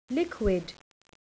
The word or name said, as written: liquid